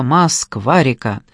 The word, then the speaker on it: Москва-река: Или лучше в одно слово «Москва-река», с единственным ударением на среднюю букву «А», которая не должна склоняться.